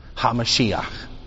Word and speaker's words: HaMashiach: How do you say the Messiah in Hebrew? HaMashiach.